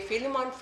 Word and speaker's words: Philemon: Philemon 1:6. There's actually only one chapter. So it's verse 6 in, how do you say, Philemon? – Philemon.